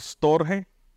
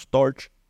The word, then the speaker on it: storge: Es amor storge, o como lo pronuncian los americanos, Storch.